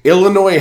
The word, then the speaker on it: Illinois: Illinois has this is huge. If Illinois beats California in the Red Box Bowl on December 30th, you can never pronounce the S in Illinois ever again.